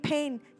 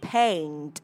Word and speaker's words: paint: Đó là mình không đọc là paint paint giống như âm E ở trong từ pen của cây bút, mà mình đọc là paint ha.